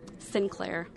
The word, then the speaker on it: Sinclair: Sinclair.